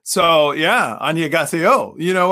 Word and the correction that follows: 안녕하세요: Oh, actually I said it wrong. It's 안녕하세요. That's Korean for hello.